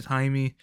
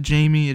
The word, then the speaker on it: Jaime: It's spelled J-A-I-M-E, just so y'all know. It's not the other way around. It's not Jamie, it is Jaime.